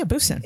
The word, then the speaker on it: Busan: And we are talking about a breakout hit, Train to Busan. – Mm-hmm. Yeah, Busan. – It is awesome. – It is amazing. – On good authority, I mean, is how you should pronounce that.